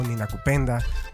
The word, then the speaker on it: Ninakupenda: Y pues vamos a seguir aquí platicando con las compañeras del colectivo Nina Cupenda, porque... Sí lo dije bien, y sin leerlo.